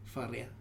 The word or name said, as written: Farea